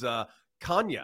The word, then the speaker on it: Canha: Mark Canha was on ESPN, mic'd up, and he actually said, yeah, the actual pronunciation is Canha.